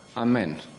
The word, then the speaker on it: amen: U pravoslavnom svetu, kao što znate, koristi se oblik koji je po grčkom čitanju, koji se po grčkom čitanju izgovara kao amin, a na zapadu se koristi oblik koji se zbog latinskog čitanja grčke ite, odnosno ete, do danas izgovara kao amen u različitim, dakle, jezicima zapadnim.